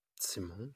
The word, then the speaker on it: Simon: I know, Simon, it's like in Spain, it's they put the intonation at the O, and in German it's on the I. It's Simon.